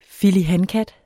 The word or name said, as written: filihankat